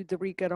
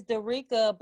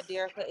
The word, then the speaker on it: Derricka: Because it's Derricka, but you know, I go by Derricka as well, but my name is pronounced Derricka. – Okay. – So, yes. – And that's D-E-R-R. – Yep. – I-C-K-A. Okay, so mm-hmm. D-E-R-R-I-C-K-A is the spelling of her first name.